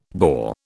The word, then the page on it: boar: British English